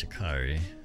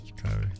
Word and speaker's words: Sha'Carri: Uh, her name's Sha'Carri. That's how, how you say it. Sha'Carri.